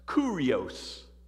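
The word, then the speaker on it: Kurios (κύριος): The Greek word is actually pronounced Kurios. Kurios.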